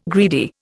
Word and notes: greedy: Indian

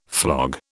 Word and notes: flog: British English

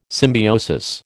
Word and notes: symbiosis: American English

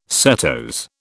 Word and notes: setose: British English

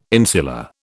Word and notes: insular: British English

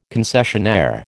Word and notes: concessionaire: American English

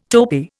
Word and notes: topi: Indian

